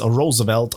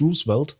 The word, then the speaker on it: Roosevelt: Eben so wie damals der Roosevelt, der halt wirklich, weil er Holländer ist, Roosevelt ausgesprochen wird und nicht Roosevelt.